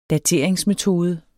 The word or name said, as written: dateringsmetode